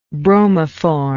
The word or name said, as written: bromoform